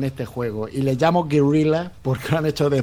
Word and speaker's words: Guerrilla: Un juego hecho por Guerrilla Games, ¿lo he dicho bien, Tony? – Guerrilla.